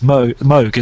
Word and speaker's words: Moog: But it is supposed to be, rhymes with Vogue. It's Moog, isn't it? – That's right. Yeah. That's the kind of, but that's even the Americanized version. There's a sort of Moog, which is the Dutch, the Dutch kind of styly, apparently.